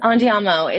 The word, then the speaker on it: Andiamo: I may get his name wrong here, but Andiamo, is that correct? Do I have that right? – Yes. Andiamo. It means let's go in Italian.